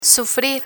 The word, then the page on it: sufrir: Spanish